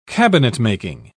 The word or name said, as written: cabinetmaking